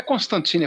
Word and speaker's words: Constantine: O, o Constantine ele vira e fala assim: não, não é Constantine, é Constantine.